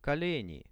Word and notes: koleni: Russian

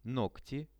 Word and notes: nogti: Russian

